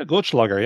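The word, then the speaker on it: Goldschläger: Is that Goldschlager? Did I say that right? – Yeah, Goldschlager, yeah.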